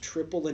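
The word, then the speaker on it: Tripel: And I found out today it's pronounced triple and double. Like, I went online and looked where there were Belgian people saying it. It's pronounced triple and fucking double.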